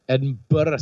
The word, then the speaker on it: Edinburgh: It's pronounced Burr, Edinburgh, Scotland.